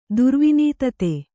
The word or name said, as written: ದುರ್ವಿನೀತತೆ